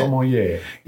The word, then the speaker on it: sommelier: Sommelier. – Yeah, there's kind of an N in there.